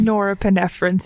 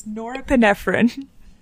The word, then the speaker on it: norepinephrine: Norepinephrine, Sabine. It's norepinephrine.